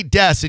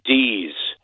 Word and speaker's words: Dees: They say Dess and you say what again? – One more time, I'm going to say it. D's.